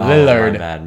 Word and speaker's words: Lillard: You need to pronounce the L's more. – Oh, Lillard.